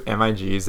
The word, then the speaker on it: MiG: People pronounce it as MiG.